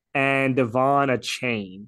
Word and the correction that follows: Achane: if I pronounced that right. – Okay. – And Achane, I just feel yeah.